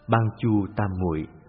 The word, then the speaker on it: Bát Châu Tam Muội: Bát Châu Tam Muội là thuật ngữ dịch âm từ tiếng Phạn, được nói rõ trong Bát Châu Tam Muội Kinh, cũng có người đọc là Ban Chu Tam Muội.